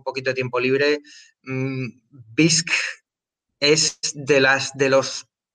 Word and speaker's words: Bisq: Pues nada, no, no, demoremos más y, saltemos al, tema que es eh, BISC, como decíamos antes, se escribe B-I-S-Q.